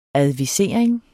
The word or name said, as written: advisering